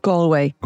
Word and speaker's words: Galway: Galway.